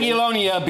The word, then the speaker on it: Jagiellonia: I just wonder what kind of chants Rangers would have come up with to rhyme with Jagiellonia. – I beg your pardon. Enough of your language. – Was that not the right pronunciation? – Jagiellonia Bialystok.